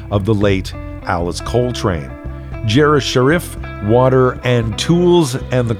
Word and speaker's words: Jairus Sharif: His name is spelled J-A-I-R-U-S-S-H-A-R-I-F, Jeref Sharif. Jeref Sharif played all instruments on the recording.